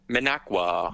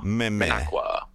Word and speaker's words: Minocqua: I'll be up in Minequa. – Minequa. – Is that Minequa? – Minequa. – Minequa. – Minocqua. – Minocqua.